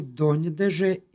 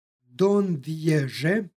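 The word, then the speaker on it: doneže: Najpre u prvoj primjeru donjedeže imamo nj izgovor, ovo je složenica, i preporuka je da se izgovara donjedeže. Postoji i drugi način izgovora, dondježe, jer je ovo složenica. Ipak mi se držimo nj izgovora, dakle donjedeže.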